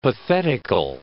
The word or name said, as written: pathetical